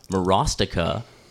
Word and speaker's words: Morostica: Was it Chantel Morostica rhymes with swastika?